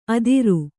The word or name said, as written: ಅದಿರು